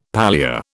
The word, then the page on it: palea: British English